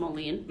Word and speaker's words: Moline: So she was murdered in 2005 in East Moyland. Is that how you say it? – I think it's Moline. – Moline, Illinois.